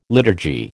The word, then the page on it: liturgy: American English